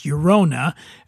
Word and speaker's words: Llorona: And that's Llorona, pronounced Llorona.